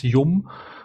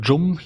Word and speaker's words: Yum: der Paketmanager heißt übrigens Yum oder Jum, je nachdem, wie man es aussprechen möchte, oder Jam. – Ich hätte Yum gesagt, aber ja.